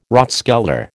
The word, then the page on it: rathskeller: American English